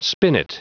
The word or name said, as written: spinet